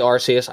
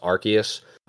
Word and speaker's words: Arceus: I say Arceus. I think technically it is pronounced Arceus, but I've always said Arceus.